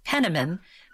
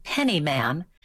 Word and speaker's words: Penniman: Remember, it was Penniman, but a lot of people would call him Pennyman.